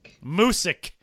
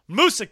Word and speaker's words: Moosic: Moosic. – Moosic,